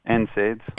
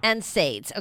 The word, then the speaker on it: NSAIDs: NSAIDs. – NSAIDs.